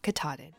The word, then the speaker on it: Katahdin: It's Mount Katahdin.